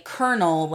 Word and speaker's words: colonel: That's spelled K-E-R-N-A-L. They would, or E-L. They would say colonel, like C-O-L-O-N.